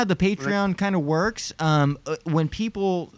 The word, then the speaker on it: Patreon: I think I pronounced it right. Patreon.